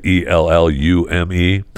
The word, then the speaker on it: Ellume: Illume, I guess that's how you say it, Ellume, is recalling its at-home COVID-19 test over the potential of false positive results.